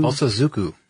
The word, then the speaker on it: Bosozoku: how do you say it again? Bosozoku? – Bosozoku. – Thank you. Sorry, guys. It's the, Aichi, A-I-C-H-I prefecture, also followed by Tokyo, Osaka.